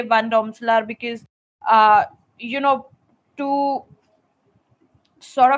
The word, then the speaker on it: Van Domselaar: I would put Van Domselaar at that spot.